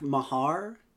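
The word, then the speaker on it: Meagher: By the way, learned that Rick Meager's name is not pronounced Rick Meager and is pronounced, I think, Rick Mahar.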